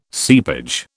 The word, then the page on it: seepage: British English